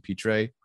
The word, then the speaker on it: Pitre: If I did not get someone like a Trent McDuffie early on, I would have gotten maybe like a Jalen Pitre, that's how you say his name, from Baylor, a guy who can play slot corner a lot.